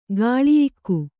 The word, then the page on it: ಕೌಳೇಯಕ: Kannada